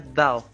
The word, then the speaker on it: Dao: É uma palavra de origem chinesa, a pronúncia é Dao.